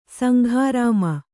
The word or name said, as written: ಸಂಗ್ರಾಮ